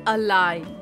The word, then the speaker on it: align: The word of the day is अलाइन।